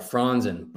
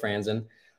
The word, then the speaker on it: Franzen: Yes, Caleb Franzen, the last name. It's some of my German heritage. So, yeah. Anyway, some people, I think the actual pronunciation is more like Franzen, but here we are in the States, so we're gonna go with Franzen.